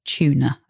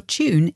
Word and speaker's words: tune: So tune is spelt T-U-N-E. But in British English, we say tune. So as though it's spelt C-H-Y-O-O-N. And in American English, it's more like tune, T-O-O-N.